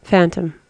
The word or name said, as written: phantom